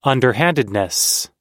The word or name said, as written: underhandedness